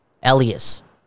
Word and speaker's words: Elias: Because I'm seeing more people just pronounce it Elias. – I think the Swedish, he had that one interview in his rookie season. He said the Swedish way was Elias Pettersson.